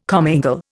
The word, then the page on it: commingle: Indian